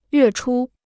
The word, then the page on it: 月初: yuè chū